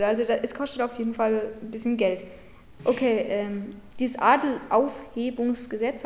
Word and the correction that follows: Adelsaufhebungsgesetz: habe ich das richtig gesagt? Ich glaube schon. – Adelsaufhebungsgesetz.